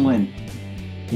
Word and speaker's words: Nguyen: Tung Nguyen. – Win? – Yeah, like win, kind of. Like the G silent.